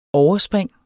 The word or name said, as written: overspring